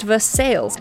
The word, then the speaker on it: Versailles: The city's name is pronounced Versailles, an anglicization different from the French pronunciation of the royal city of the same name near Paris.